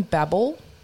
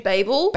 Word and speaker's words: Babel: Have you seen Babel? – Do you mean Babel? – Babel. I never pronounce it right.